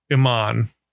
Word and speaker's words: Iman: She's like in between Brie and Iman. Is that how you say her name? – Yeah.